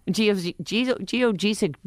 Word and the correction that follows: geodesic: It's pronounced geodesic. – Okay. – Like desk.